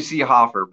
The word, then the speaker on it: Seehofer: Yeah, it's actually Seehofer, but I never correct anybody when they say Seehofer.